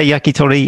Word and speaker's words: yakitori: His favorite type of Japanese food is yakitori. I hope I'm pronouncing it well. Welcome. – Very good. – Yeah. Japanese. – Yeah, it's good. Yeah, yakitori. Yeah, that's right.